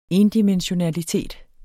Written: endimensionalitet

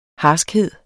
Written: harskhed

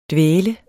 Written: dvæle

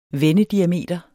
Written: vendediameter

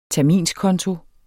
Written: terminskonto

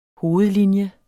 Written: hovedlinje

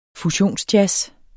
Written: fusionsjazz

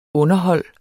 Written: underhold

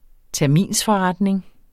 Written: TERMINSFORRETNING

